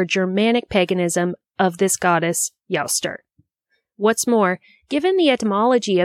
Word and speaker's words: Eostre: I should note that Jostr is spelled E-O-S-T-R-E, and so it could be pronounced Eostr or Uster, but the internet told me Jostr, and so that's what I'm going with.